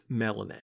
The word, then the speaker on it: Mallonee: Malinet's name is spelled M-A-L-L-O-N-E-E, but according to a note in the first issue, it is pronounced Malinet.